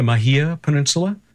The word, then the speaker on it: Mahia Peninsula: And then New Zealand with that last entirely thanks to your complex on the, am I pronouncing it correctly, the Mahia Peninsula? – That's correct.